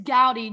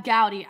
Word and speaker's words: Goudy: It's Gowdy. G-O-U-D-Y, Gab Gowdy.